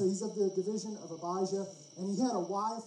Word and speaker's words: Abijah: And then my wife pointed out, you know, it looks a lot like Elijah from the Old Testament. And that makes sense. And so we're Abijah, that's how you say it.